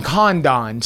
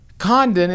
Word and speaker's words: Condon: But the announcers call him Condon, C-O-N-D-O-N. – Like around here, Condon is, I mean, that's how you spell it.